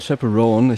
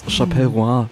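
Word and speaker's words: Chappell Roan: Nämlich, oh mein Gott, dieses Mal kann ich es richtig aussprechen. Pink Pony Club von Chapel Roan. Ich habe es Chapel Roan das letzte Mal ausgesprochen, wie ein Vollidiot.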